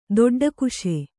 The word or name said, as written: ದೊಡ್ಡಕುಶೆ